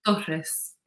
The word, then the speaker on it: Torres: It's Torres. You have to roll it a little bit.